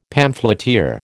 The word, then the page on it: pamphleteer: American English